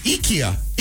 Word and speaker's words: IKEA: This says it's IKEA. IKEA.